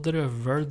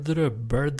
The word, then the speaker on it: drøvel: Uh, uttale altså drøvel, drøbbel, drøppil og drippill til og med, så det, det, det, har vært mange varianter på den der.